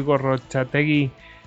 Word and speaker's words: Gorrochategui: creo que en vasco se pronunciaría Gorrochategui.